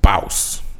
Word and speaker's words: Baus: Baus. – You have to say it like Rick Ross did 5 years ago, because that's how cool we are.